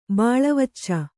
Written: ಬಾಳವಚ್ಚ

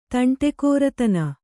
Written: ತಂಟೆಕೋರತನ

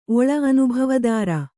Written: ಒಳ್

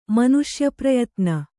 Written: ಮನುಷ್ಯಪ್ರಯತ್ನ

